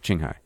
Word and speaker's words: Qinghai: Yeah, you were close. – Qinghai. Qinghai. – I think I said Qi, but Qinghai.